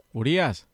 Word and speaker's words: Urias: No, it's not. It's Urias. – Urias. – That's it. – Urias. So that's it. It's that simple.